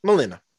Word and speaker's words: Molina: But just the fact, I was already hyped that Doc Ock was in it, played by, I forget his name, Alfred. Molina? – Alfred Molina. – I don't know how to say his last name, but Molina.